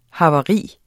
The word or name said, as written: havari